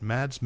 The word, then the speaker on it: Mads: Mads Mikkelsen, mm-hmm.